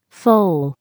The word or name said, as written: foal